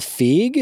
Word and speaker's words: Feig: Because because when they announced it, uh, Paul Feig is that how yep.